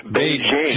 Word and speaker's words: Beijing: Beijing. Jing, jing, jing, like jingo.